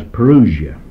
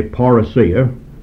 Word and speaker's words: parousia: This word is spelled P-A-R-O-U-S-I-A. You may have heard it English, and men will pronounce it as parousia. I'm dealing with the Greek word, and therefore I will say parousia, and not the word parousia, for parousia would be the correct definition, the correct pronunciation in its Greek form.